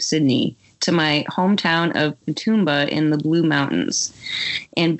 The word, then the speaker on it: Katoomba: Very good pronunciation on the Australian names there. So I thought Katoomba might. – Okay, good. – I thought Katoomba might trip you up, but it was perfect.